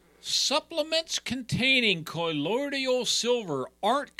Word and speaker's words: colloidal: Okay, colloidal silver. You mispronounce it. – Shocker. – Actually, I thought I nailed that one. – No. Colloidal silver, not colloidal.